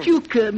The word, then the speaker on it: Tucker: Tuker, Mr. Kendall. It's pronounced Tuker.